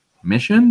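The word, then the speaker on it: Missiaen: And his name was Jason Mission, who is spelled M-I-S-S-I-A-E-N. I wasn't sure how to pronounce it until I saw a video, and I was like, wait, Mission? That's it?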